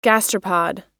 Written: gastropod

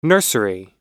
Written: nursery

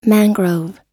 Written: mangrove

